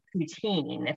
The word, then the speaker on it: poutine: So we stayed in there and then Emily went over and got that traditional poutine, if that's how you pronounce it. Is that how you pronounce it? – Yep.